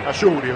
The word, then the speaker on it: Ashouri: Ashouri, me parece, ese se pronuncia correctamente.